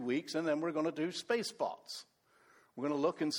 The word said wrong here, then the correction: Spacepets: Spacepots. I keep saying it, don't I? Spacepets. Spacepets. It's not an acronym if you actually say it right.